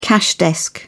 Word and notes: cash desk: British English

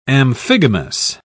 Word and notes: amphigamous: British English

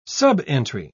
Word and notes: subentry: British English

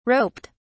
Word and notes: roped: British English

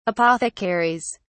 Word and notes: apothecaries: British English

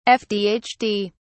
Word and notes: fdhd: British English

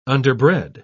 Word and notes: underbred: British English

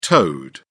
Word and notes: toed: British English

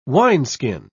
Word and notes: wineskin: British English

